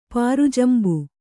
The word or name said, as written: ಪಾರುಜಂಬು